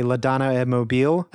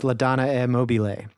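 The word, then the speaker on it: La Donna è Mobile: I say La Donna e Mobile. Actually, the pronunciation is La Donna e Mobile.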